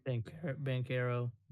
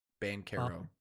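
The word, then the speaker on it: Banchero: So, like, Christian Wood and Pinkerel. How do you say his last name? – Bankero. – It's a B. Bankero.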